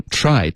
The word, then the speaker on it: try: 首先 try T R 的这个音呢，是要卷舌，OK，吃有点像吃的这个音，可是再往后卷一点都可以。